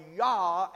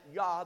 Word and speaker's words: Jah: And Jah or Yah, as it's pronounced, which is an abbreviation of Jehovah, is found in verse number 4. Blessed be the Lord, Yah, abbreviation for Jehovah, Jah, or some pronounce that Yah. There's two different pronunciations for the word J-A-H, Yah.